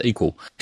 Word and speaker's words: EQL: That's EQL, pronounced equal.